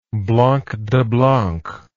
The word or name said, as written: blanc de blancs